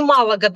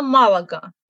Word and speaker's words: Малага: Ну и Малага, да, то же самое. Правильно, Малага. И я надеюсь, что в русских словарях будет Малага тоже.